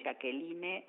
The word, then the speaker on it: Jacqueline: @ Jacqueline Bochar, mi nombre se escribe con J y con CQ, Jacqueline Bochar,